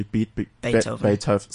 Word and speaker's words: Beethoven: So there you are, you're referencing the likes of Beethoven, et cetera, et cetera. Am I pronouncing correctly? – Beethoven. – Sorry, there we go.